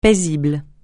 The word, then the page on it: paisible: francais